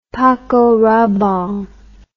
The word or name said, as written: paco rabanne